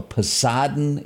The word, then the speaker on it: Poseidon: A movie called, and they remade it, called The Poseidon Adventure.